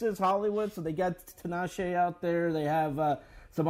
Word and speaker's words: Tinashe: Is it Tinashe? – Tinashe, yeah.